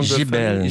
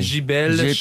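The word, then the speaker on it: Giébel: Comment on prononce son nom? Gibel. – Gibel. – Faut allonger le J-E-B-E-L. Ça s'écrit J-I-E-B-E-L. – J-I-E-B-E-L chez Fleuve Noir, un bouquin qui a été publié en 2013.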